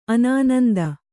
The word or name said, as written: ಅನಾನಂದ